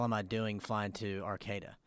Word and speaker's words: Arcata: It's not Arcata, California. – Uh-huh. – It's Arcata.